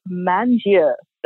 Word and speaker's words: Mangia: So S-A-N-D-R-A-M-A-N-D-I-E. I know that people have a hard time pronouncing my middle name. I'm glad that you're pronouncing it too, because I'm sure they're like, say that again. Yeah, it is Mangia. That's how you really pronounce it.